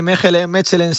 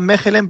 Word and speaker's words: Mechelen: ¿Lo he dicho bien? – Sí, Mechelen, sí. Un poco, aquí se dice más Mechelen, pero hay gente también que lo dice Mechelen. Yo creo que depende un poco.